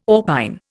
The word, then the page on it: orpine: Indian